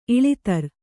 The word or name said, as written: ಇಳೆದೇರ